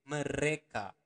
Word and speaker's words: mereka: Remember to pronounce the R uh, clearly. Mereka.